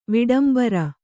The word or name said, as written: ವಿಡಂಬರ